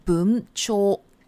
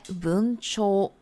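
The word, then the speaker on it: 文鳥: 好，文鸟日文叫做 buncho。好，因为文呢，文章的文就是 boon，然后鸟呢，好，另外一种说法就是 cho，汉字的，好，那种汉语发音呢就是 cho，所以加起来 boon cho。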